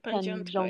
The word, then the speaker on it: Pandziatko: I said, no, it's really cool, and I'm gonna learn how to say it. So I, Panjoshik and Pandziatko, yes. – Pandjonka.